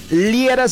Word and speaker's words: Liera: Y a mí, por mi nombre, Fernando Liera, se escribe L-I-E-R-A.